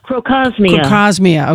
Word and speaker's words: Crocosmia: Another one that I have just fallen in love with, and it's pretty inexpensive, that Croxomia, did I say that right? Croxomia? – Crocosmia, okay.